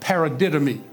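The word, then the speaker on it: paradidomi: It ends with an I, but it's a long E sound. Paradidomy.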